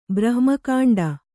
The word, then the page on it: ಬ್ರಹ್ಮಕಾಂಡ: brahma kāṇḍa